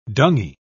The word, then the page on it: dungy: British English